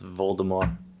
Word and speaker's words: Voldemort: And actually, the- that's not how you pronounce it. – Enlighten me. It's Voldemort. You don't pronounce the T.